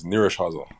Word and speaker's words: Nyíregyháza: It's Nyíregyháza.